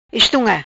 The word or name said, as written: Estunga